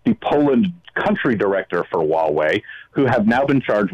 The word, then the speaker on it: Huawei: This highway, or how do you pronounce it, H-U-E-W-E-I, highway? – Huawei.